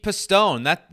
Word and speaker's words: Pistone: It is Pete Pistone. That's how you say your name, right, Petey? Pistone? – Right, that's it.